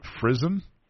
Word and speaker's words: frizzen: It allows passage of the sparks from the flint hitting the frizzen. Is that how you pronounce that? Frizzen? – Yeah.